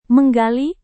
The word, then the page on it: menggali: Indonesian